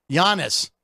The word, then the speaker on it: Giannis: Giannis. I didn't hard G it.